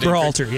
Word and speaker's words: Berhalter: I want to look at the coach, Greg Berhalter. Is that how you pronounce it? – Berhalter, yeah.